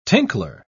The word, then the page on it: tinkler: British English